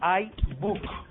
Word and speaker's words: iBook: Hay que pronunciarlo iBook. Hay que pronunciarlo iBook. La I es I. Porque si no, luego con. Si uno dice ebook, ya se empieza un poco a una liada. Es iBook.